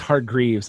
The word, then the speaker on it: Hargreaves: Hargraves. – And by the way, I just realized I looked it up. It's Hargreaves. I am so sorry. It's Hargreaves. That's my fault. I said it wrong.